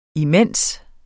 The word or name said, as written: imens